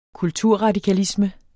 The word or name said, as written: kulturradikalisme